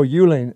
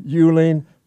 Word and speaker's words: Uehling: Oh, Euling. Oh, yeah, that's true. U-E-H-L-I-N-G. Thank you very much, Kevin. Okay, it's not an easy name to spell. Ed Euling for Lieutenant Governor, U-E-H-L-I-N-G.